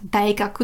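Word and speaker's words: Daigaku (大学): Und bei Daigaku eben kommt da hinten nichts dran, deshalb spricht man es dann ganz normal Gakku aus. Daigaku, das große Lernen, die Uni.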